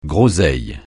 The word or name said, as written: groseille